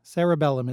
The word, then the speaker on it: cerebellum: Cerebellum. – Pronounce it a little bit differently, and you're getting there. – Oh, okay. Cerebellum? – Cerebellum is correct, with the E-R-E-B-E in the middle.